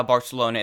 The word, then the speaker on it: Barcelona: And no, that's not just my lisp, that's how Barcelona is actually pronounced.